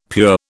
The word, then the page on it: pure: British English